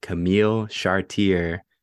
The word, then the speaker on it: Camille Chartier: We have Camille Chartier.